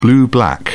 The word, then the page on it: blue-black: British English